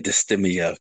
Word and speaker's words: dysthymia: dysthymia. – Yeah, I have a hard time pronouncing that word. – Yeah, dysthymia, correct.